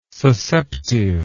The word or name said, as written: susceptive